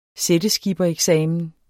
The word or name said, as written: sætteskippereksamen